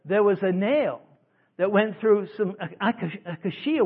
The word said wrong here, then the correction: acacia: wood, how you pronounce it? Acacia. Thank you, Mama. Acacia wood is one of the hardest woods because it grows in the desert.